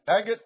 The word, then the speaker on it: agate: And the third row, a ligure, and an agate, and an amethyst. Agate, okay, thank you. I was taught if it's got an E at the end of it, it's long A.